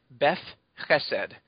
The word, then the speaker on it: Beth Chesed: It's B-E-T-H, as in the name Beth, which is Hebrew word for house. And Chesed is C-H-E-S, as in Sam, E-D.